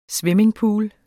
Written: swimmingpools

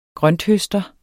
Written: grønthøster

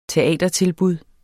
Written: teatertilbud

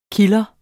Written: KILDRERNES

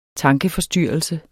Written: tankeforstyrrelse

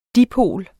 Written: dipol

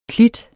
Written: klit

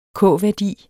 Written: k-værdi

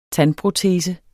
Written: TANDPROTESER

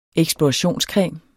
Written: eksplorationscreme

